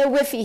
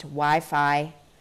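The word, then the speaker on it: Wi-Fi: the whiffy here is terrible. Mom, it's pronounced Wi Fi Curtis risked a quick peek at the table.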